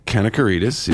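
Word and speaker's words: Kanakaredes: On Friday nights called Providence, and it was I remember that with Maria Kanasarada. – Maria Kanakaridis, yes. Thank you. That was the first thing I learned is how to pronounce the star's name.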